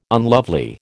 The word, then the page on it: unlovely: American English